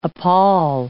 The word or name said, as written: appal